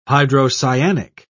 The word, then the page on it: hydrocyanic: British English